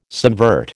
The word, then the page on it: subvert: American English